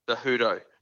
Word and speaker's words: Cejudo: Cejudo? – Cejudo. – Cejudo.